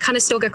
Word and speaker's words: Conestoga: Now you're at Conestoga. Did I say that right? – Yeah, I'm at Conestoga College.